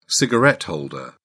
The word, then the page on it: cigarette holder: British English